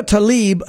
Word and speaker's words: Tlaib: How do you pronounce that? – Tlaib. Because it's Tlaib. – Even though it's spelled T-L-A-I-B. – Yeah, they pronounce it Tlaib.